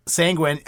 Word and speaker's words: sanguine: And I uh, had to look up the pronunciation because uh, I do that from time to time. Sanguine. Uh, yeah, because it does not look like Gwen.